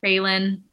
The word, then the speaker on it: Raelin: Raylan.